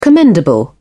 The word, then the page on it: commendable: British English